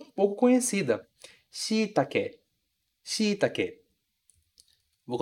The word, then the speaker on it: shiitake: Veja que a ênfase está lá no shi. Shiitake. Shiitake. Outro cuidado muito grande que você tem que ter, que é extremamente importante, é esse e do final ser pronunciado realmente como e. E a gente não transformar em i, como algumas vezes a gente acaba fazendo. Então, shiitake, com e no final, sem transformar em i. A palavra em português acaba virando shiitake, shiitake, com i. E o ideal é a gente pronunciar shiitake, com e no final mesmo, muito bem pronunciado.